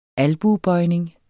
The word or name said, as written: albuebøjning